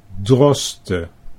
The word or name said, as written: Droste